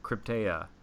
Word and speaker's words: Krypteia: So you, and correct me if I'm wrong with the pronunciation, but you are one of the Krypteia for running the Hurricane Heats.